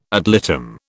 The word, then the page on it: ad litem: British English